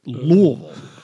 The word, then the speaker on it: Louisville: You got to overpronounce the U. Louisville.